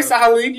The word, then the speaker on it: Sahalin: They have an experimental mobile armor, I believe it is, and their pilot is Aina Sahalin, something like that. – You said it right, Sahalin. You got it right.